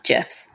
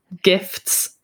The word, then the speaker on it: GIFs: So there's a gallery of gifts, mm-hmm, like images, but like, I think it's actually pronounced GIFs. – Okay. I hear you. GIFs, not gifts. Without, yeah, the T, but then the age-old debate if it's GIF or JIF.